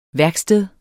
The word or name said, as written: værksted